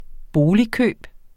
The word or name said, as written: boligkøb